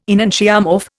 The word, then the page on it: enantiomorph: Indian